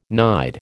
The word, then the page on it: nide: American English